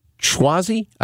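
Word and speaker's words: Suozzi: Schwozzi, I think is how you say this guy's name. It's S-U-O-Z-Z-I.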